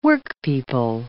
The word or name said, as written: workpeople